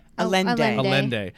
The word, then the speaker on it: Allende: Means of propulsion they might use, received two letters from Carlos Miguel, how do you say it again? – Allende, who identified himself as Carl M.